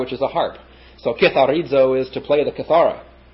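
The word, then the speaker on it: kitharizo (κιθαρίζω): Next we have katharizo. Not katharizo, kitharizo. Kitharizo. K-I-T-H-A-R-I-Z-O. Hadn't thought of that. Katharizo is to cleanse.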